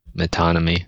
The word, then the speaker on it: metonymy: I think it is pronounced the way you've been pronouncing it, metonymy.